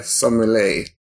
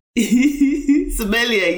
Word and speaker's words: sommelier: She's a board of director for Birmingham Royal Ballet, as well as first black female beer sommelier. I'm not sure how you say that correctly. – Sommelier, you're there, sommelier, yeah.